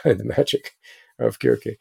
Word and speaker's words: Circe: Um, the C's are pronounced "cuz" in the original Greek. So Circe was actually pronounced Kirke.